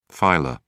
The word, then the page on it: filer: British English